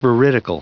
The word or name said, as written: veridical